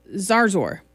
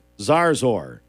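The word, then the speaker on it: Zarzour: Going to join Taylor Zozar, I don't know how to say it. Anyway, so, yeah. – Andy Morris sent us that pronunciation earlier, Zarzor. – Zarzor.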